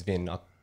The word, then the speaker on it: Vin: And I don't normally tell people my name's Vin.